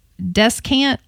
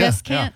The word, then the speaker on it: descant: And I know I'm going to pronounce this one wrong. Descant. – No, actually, that's... – Is it how you say correct. – It? – Correct. That's right. Yeah. – Descant. – Yeah. – D-E-S-C-A-N-T.